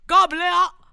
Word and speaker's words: Gabalier: The song came out that he collaborated with Austrian singer Andreas Gabalier. Is that a French way of saying it? Gabalier. How do you say it in Austrian with an Austrian accent? – I believe it's exactly that.